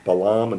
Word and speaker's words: Balaam: Yes, in some of the non-Orthodox books, it's spelled B-A-A-L-A-M. And I've heard it pronounced Balaam, and I've heard it pronounced Bilam. – I read Hebrew. I don't read good English. I can tell you that in Hebrew it's Bilam.